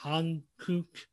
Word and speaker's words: Hankuk: When I see Korean culture, Hankuk, I'm probably saying that wrong. – No, you said that right.